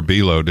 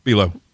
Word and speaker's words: Bi-Lo: Was that the one that was spelled B-I-L-O? – Yeah, Belo. – Well, those singers said it wrong.